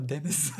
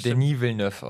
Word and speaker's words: Denis Villeneuve: Denis Villeneuve, ich sag immer Dennis. – Der Dennis. Villeneuve. Ich meine, wenn ich nicht komplett falsch bin, ist es Denis Villeneuve ausgesprochen.